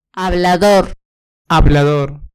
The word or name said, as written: hablador